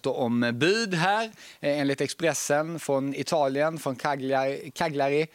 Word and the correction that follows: Cagliari: Det var ingen bra uttal. – Vad säger man då? Cagliari? – Cagliari. – Cagliari, okej. Okay.